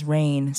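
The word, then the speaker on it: Rayne: my pen name is Rain, so you can look up Rain Bey, and it's spelled R-A-Y-N-E-B-E-Y.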